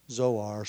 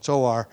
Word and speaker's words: Zoar: Zoar, spelled either with a TS at the beginning or a Z at the beginning, either way. Generally, but pronounced the same way with either spelling. Zoar, it's the Hebrew word for little.